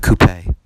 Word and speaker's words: coupe: And it's not a coupe. It's a coupe is what, it's not a coupe. Coupe is how you say the word. That's how the Europeans say it.